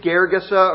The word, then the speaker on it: Gergesa: Mark and Luke get a little more specific and tell us the particular area of that country, the particular village, Gergesa or Gerasa, the slightly different pronunciations, but they're the same town, they're the same place.